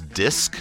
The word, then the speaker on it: Disq: Back on the Athens 441 playlist, that was Disc, spelled with a Q, and their song Daily Routine.